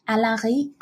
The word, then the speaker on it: Alarie: And it's called, what, Ludovic Alari. – Alari. They just spell that. – A-L-A-R-I-E.